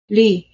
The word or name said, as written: leigh